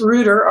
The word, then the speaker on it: Ruhter: Yeah, so it's Ruhter, R-U-H-T-E-R, Bison, B-I-S-O-N.